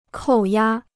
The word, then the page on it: 扣押: kòu yā